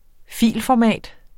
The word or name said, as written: filformat